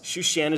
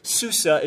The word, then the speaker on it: Shushan: Shushan is the Hebrew pronunciation of this word. Susa is the Greek pronunciation of this city.